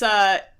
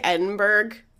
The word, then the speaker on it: Edinburgh: Oh, it's Edinburgh. So it looks like Edinburgh, but it's pronounced Edinburgh.